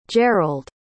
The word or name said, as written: jerrald